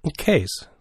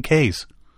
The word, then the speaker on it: cays: Now, they talk about C-A-Y-S. – Keys. – Keys. – Oh, is that how they spell it? That's how they spell it. Yeah, because I've always called them K's.